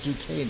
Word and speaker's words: Duquesne: Yeah, and it's Duquesne in Pittsburgh. Yeah. Like D-U-K-A-N-E, phonetically.